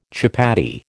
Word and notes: chapatti: American English

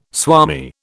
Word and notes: swami: British English

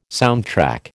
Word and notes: soundtrack: American English

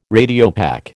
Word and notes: Radiopaque: American English

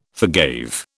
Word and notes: forgave: British English

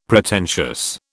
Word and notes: Pretentious: British English

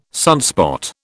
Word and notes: sunspot: British English

